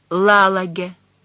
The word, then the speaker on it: Лалаге: «Как ее зовут?» «Лалаге». Она произнесла это необычное имя с ударением на первом слоге, по-прежнему глядя в пол.